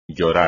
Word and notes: llorar: Spanish

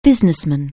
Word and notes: businessman: anglais

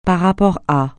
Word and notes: par rapport à: francais

